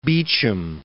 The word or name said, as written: Beecham